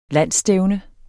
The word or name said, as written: landsstævne